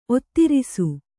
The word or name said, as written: ಒತ್ತಿರಿಸು